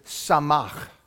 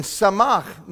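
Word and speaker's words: samach (שָׂמַח): You got to say it with ech. If not, it's not the right way to say it. It's samach. And samach means exceedingly and arrogantly joyous.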